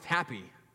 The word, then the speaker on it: Hapi: The god Hapi, H-A-P-I, pronounced Happy, was the Egyptian god of the Nile.